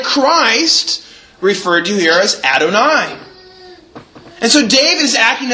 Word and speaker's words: Adonai: And if you pronounce that word from Hebrew, it's pronounced Adonai. Adonai.